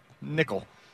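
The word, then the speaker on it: Nickell: Nickel.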